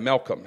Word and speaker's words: Milcom: M-I-L-C-O-M. Malcolm is how I pronounced it.